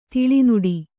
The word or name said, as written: ತಾಳೆ ನೋಡು